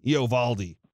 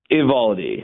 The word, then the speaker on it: Uvalde: Uvalde. – I think it's just Uvalde. – Uvalde. – Imagine the O's not there.